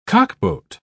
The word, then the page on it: cockboat: British English